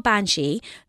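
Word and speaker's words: Beansí: They're called Banshee. They spell it B-E-A-N-S-I Fada.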